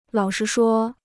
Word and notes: 老实说: lǎo shí shuō

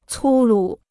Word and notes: 粗鲁: cū lǔ

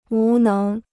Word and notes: 无能: wú néng

